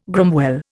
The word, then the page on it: gromwell: Indian